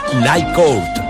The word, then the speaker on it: Night Court: Però pues esta és la sèrie misteriosa Juzgado de Guàrdia, Night Court, que es pronuncia així en anglès, ja, sí.